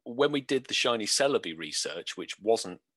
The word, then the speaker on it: Celebi: You know, I like I like the Celebi.